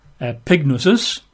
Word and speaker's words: epignosis: It's got an E-P-I at the front. E-P-I. Looks like epignosis. Epignosis. Comes through with pigs. But it's spelled that way, epignosis, epignosis.